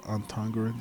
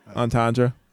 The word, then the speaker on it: entendre: Double-edged sword, double, double, double entendre. That's how you say it. – Entendre.